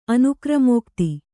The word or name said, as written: ಅನುಕ್ರಮೋಕ್ತಿ